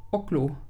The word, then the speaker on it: Ocllo: Ich weiß nicht, wie das ausgesprochen wird. Ich nenne es jetzt mal Ocllo. Hat sich jetzt so ein bisschen russisch angehört. – Ocllo. Ocllo? – Ja, das ist schon wieder besser. – O-C-L-L-O. Wie soll ich denn das aussprechen? Ocllo. – O-C-L-L-O. – Ocllo. – Ocllo. Nee, das ist. Ocllo, ja, ist besser.